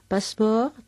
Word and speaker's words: passeport: passeport tiene una E que no oímos realmente.